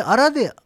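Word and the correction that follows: 嵐電: アラデンって読むんですか、ランデンって読むんじゃないですか。 – あランデンなんですか。僕アラデン、アラデン言ってましたけど。 – あら嵐でしょ、嵐の電車でしょ。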